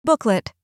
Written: booklet

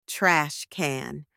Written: trash can

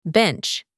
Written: bench

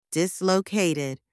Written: dislocated

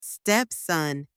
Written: stepson

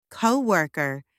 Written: coworker

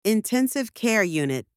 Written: intensive care unit

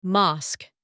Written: mosque

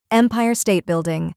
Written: Empire State Building

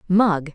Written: mug

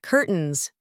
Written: curtains

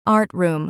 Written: art room

